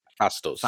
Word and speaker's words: Phastos: We're getting a Eternal Season that's got Arashim, Gilgamesh, Phantos, is that how you say it? – Phastos. – Phastos, Circe, Athena.